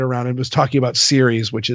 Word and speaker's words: Ceres: Like Ceres, like the goddess? – The goddess, yes.